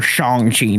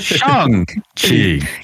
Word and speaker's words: Shang-Chi: And then yeah, the director came out for like Asian heritage and was like, "Hey, this is the actual pronunciation." And then even in the trailer, at the very end, it goes like, you know, buy your tickets for Shang-Chi now. It's like, okay, all right, confirmed. I've been saying it wrong.